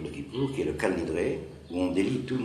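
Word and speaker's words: Kol Nidre: Et si c'était de l'hébreu, on dirait kol hanedarim. En araméen, on dit kal nidre. Avec un kamat, mais ça se prononce kal.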